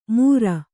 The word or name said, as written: ಮುರ